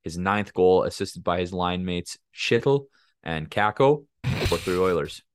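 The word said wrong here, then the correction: Chytil: Isn't that how you say it? – It's Heedle. – It's Heedle. – Yeah. – You show me a linguistics breakdown how that's he. Oh, I guess there's the C is silent, hey?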